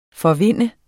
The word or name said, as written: forvinde